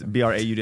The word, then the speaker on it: Braud: No, it's Braud. It's like broad, say it wrong because I'm from Louisiana.